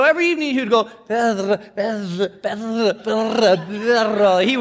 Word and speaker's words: perro: He would say, "How do you say, see, the word dog in Spanish is perro. And you have to roll your R's.